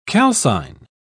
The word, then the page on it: calcine: British English